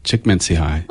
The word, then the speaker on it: Csikszentmihalyi: Also, this article talks about, there's a psychologist, I can't say his name, Mihaly Csikszentmihalyi. – I can do this. Csikszentmihalyi.